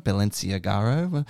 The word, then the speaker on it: Balenciaga: a brand like Balenciaga. How do you pronounce it? – Balenciaga.